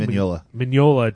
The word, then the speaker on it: Mignola: Mignola doesn't play games.